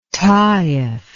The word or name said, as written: Taif